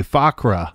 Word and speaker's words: Fuqra: Fakwa, And I'm not saying that right. F-U-Q-R-A, Fakra.